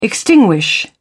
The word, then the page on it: extinguish: British English